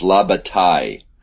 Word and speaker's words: Bataille: It's La Bataille. Don't pronounce the L. Don't pronounce the L. – Why? – Yeah, la. – Why are they there? – They are there to make your life crazy, but I know. It's like in Spanish, you don't pronounce the double L.